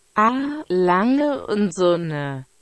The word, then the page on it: A Lange and Sohne: German